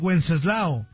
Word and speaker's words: Wenceslao: Es lo mismo que Wenceslao. Wenceslao. Nomás tú no ve. – Se mancharon. – Pero San Wenceslao. Se pronuncia igual.